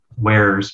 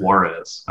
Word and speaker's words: wares: so I knew like wares, but back then I didn't know how to pronounce it. I, I called it Juarez.